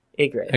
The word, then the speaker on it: Ygritte: We constantly say that wrong. – Ygritte. – I apologize. – I kind of just try to say it as fast as I could, so no one Ygritte. Like the bird.